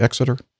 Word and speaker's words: Exeter: Am I saying that right, Exeter? Okay. – Yes, you are, sir.